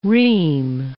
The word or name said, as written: ream